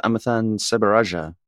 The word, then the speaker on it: Amiththan Sebarajah: Hey, we're rolling with Amiththan Sebarajah.